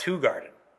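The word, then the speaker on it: Hoegaarden: No, it's a Hoe Garden, which is actually pronounced Hoe Garden.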